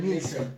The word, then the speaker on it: Neeson: ¿Cómo se pronuncia Neil? ¿Eh? Liam Nilsson. Liam Nilsson? Liam Neeson.